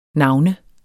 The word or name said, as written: navner